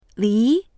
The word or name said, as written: li2